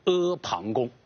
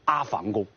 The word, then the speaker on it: 阿房宫: 由于秦朝灭亡以后还没有建造好，还没有给他一个正式的一个美丽的名字，所以天下的老百姓就称呼这个宫殿叫大山旁边的一个宫殿叫阿旁宫啊。这是我对阿旁宫的一个解释，不仅是它的词义啊，而且包括它的读音啊，这是需要大家要了解的。你最好不要读成阿房宫。